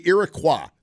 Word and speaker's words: Iroquois: They were under attack by the Iroquois. That's how they say it up there.